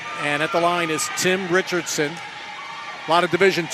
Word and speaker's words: Tym: And Richardson, pronounced Tim, spelled T-Y-M at the line, shooting one.